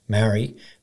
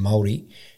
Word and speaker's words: Māori: You know, is I think when I grew up, yeah, the term was Maori, but the correct pronunciation is closer to Maori.